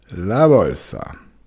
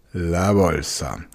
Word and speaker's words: la bolsa: Das B im Spanischen, la bolsa, wird nicht wie ein B im Deutschen ausgesprochen, sondern die Lippen berühren sich beim B nicht. Also statt la bolsa, la bolsa.